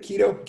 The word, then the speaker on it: Quito: And you know, what's the city? Quito. Quito. How do you say it? – Keto. Keto. Right? Yeah, keto.